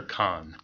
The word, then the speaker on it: Cannes: is it Cannes or Cannes? – Cannes. – Okay, it is Cannes. – Although I think the Brits say Cannes, but okay. But the Cannes is, from what I've heard, is acceptable.